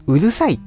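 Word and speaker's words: 五月蝿い: 5 月に、縄ですかね、でうるさ、ハエですね、うるさいって読みますね。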